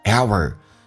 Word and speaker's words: hour: 来体会一下 a 这个发音， every hour， hour 美式发音需要轻度卷舌， hour。